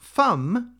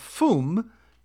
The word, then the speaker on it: fum: The carol is not pronounced fum to rhyme with drum, but fum to rhyme with room.